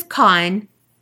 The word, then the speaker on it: Khan: My name is Khan, pronounced like Genghis Khan or Benedict Cumberbatch's character in Star Trek.